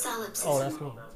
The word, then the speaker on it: solipsism: Solipsism.